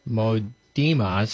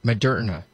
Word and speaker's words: Moderna: Another big thing in the news here on the 19th here of August, company Modema's stock surged. – Moderna.